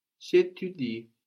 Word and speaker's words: j'étudie: J'étudie, j'étudie. So je and étudie blend together, j'étudie. And this is spelled E-T-U-D-I-E. But of course, it just sounds like étudier without the e ending.